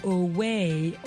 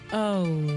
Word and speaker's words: owe: Oh, Ça s'appelle O-W-E. Ça s'écrit O-W-E. On penserait peut-être dire away ou oui. Non, ça se dit oh. Et en fait, ça s'entend comme si on parlait de la lettre O.